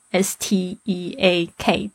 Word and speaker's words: steak: S T E A K，特别注意一下它的发音，这个 E A 发 A 的声音，steak，steak。